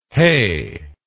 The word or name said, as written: heigh